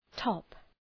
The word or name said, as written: top